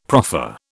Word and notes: proffer: British English

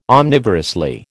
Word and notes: omnivorously: American English

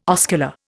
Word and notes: oscular: Indian

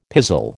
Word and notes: pizzle: American English

